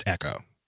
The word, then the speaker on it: Aeko: Apparently it's pronounced Echo. – Oh, Janae Echo? – No, really? Apparently the baby's name is Echo. Oh, the baby's name is Echo. Yeah. Okay. I was like, really? – But spelled like A-E-K-O.